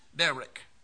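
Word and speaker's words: Derek (דרך): Strong's number 1870 in the Hebrew is pronounced Derek, D-E-H-R-E-K. Is that close? Derek.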